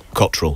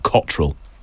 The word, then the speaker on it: Cottrell: Cottrell. Excuse me, I keep saying that wrong. Cottrell.